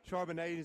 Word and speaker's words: Charbonnet: I think the guy from UCLA, Charbonnet, am I pronouncing it? – Charbonnet is an example.